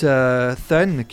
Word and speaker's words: Thun: je me demandais ce que ça voulait dire, ce t u n Et en fait, c'est une référence à Son, Son le fils. – C'est leur, leur prononciation de Queensbridge. – En okay. fait, le THUN, prononce comme ça.